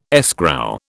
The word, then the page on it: escrow: British English